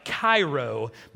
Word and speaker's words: Cairo: Now, it's spelled like Cairo, but if you're from Southern Illinois, you know it said Cairo, you know if you know kind of thing.